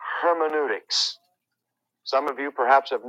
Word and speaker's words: hermeneutics: But hermeneutics, and let me just take a minute to spell it for you H E R M E N E U, hermeneutics, hermeneutics.